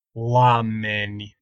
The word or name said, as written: oameni